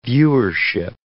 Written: viewership

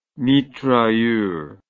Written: mitrailleur

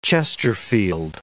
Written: chesterfield

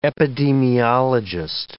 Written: epidemiologist

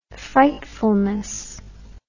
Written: frightfulness

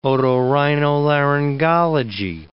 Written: otorhinolaryngology

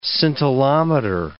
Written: scintillometer

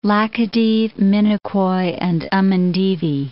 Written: Laccadive, Minicoy and Amindivi Islands

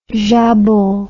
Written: jambon

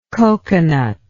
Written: cocoanut

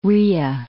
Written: rya

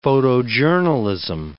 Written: photojournalism

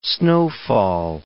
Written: snowfall

